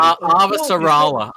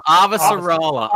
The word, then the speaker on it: Avasarala: Avasarala. Avasarala.